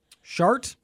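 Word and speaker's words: Schardt: S-C-H-A-R-D-T. I know. It sounds like, right? – Yeah.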